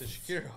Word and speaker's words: Shakira: Shakari, I believe it's pronounced. – Yes. – Shakira. I was like, why was Shakari? – Why was Shakira?